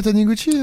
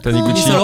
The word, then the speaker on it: Taniguchi: Ah, c'est lui, c'est Taniguchi. – Oui. – Taniguchi.